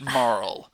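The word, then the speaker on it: Marle: I've always said it Marl. – That's pretty much, which I realize just sounds like I'm calling her Carl, but with an M.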